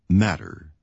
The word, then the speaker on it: matter: Notice the pronunciation of the letter T in the word matter. It sounds more like a D sound than a T sound.